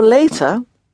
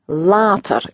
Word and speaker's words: later: The word for later, again, is exactly the same in Dutch, spelt exactly the same, but pronounced later. It's like water and later, isn't it? Later.